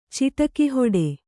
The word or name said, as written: ಚಿಟಕಿಹೊಡೆ